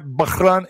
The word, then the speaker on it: Bahrain: Iran's axis of resistance claims new front against Israel, a militia based in the Arab island nation of Bahrain. Did I say that right? Bahrain, Angel Warrior, thank you.